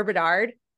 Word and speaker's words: Bedard: Unless something goes awry with Connor Bedard.